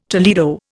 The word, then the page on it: Toledo: Indian